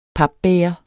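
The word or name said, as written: PAPBÆGER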